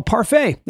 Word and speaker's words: parfait: Oh, parfait. That's it. I know what it is now. As you read, you go, oh, you don't pronounce the T because it's French, probably.